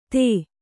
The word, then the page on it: ಥೈ: thai